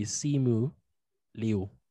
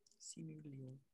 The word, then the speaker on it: Simu Liu: but he did tweet how to say it, and I tried. Anyway. – How do you say it? – It's literally Simu Liu. – Simu Liu.